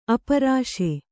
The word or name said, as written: ಅಪ್ಪರಿಸು